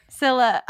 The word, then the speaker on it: Scylla: Scylla.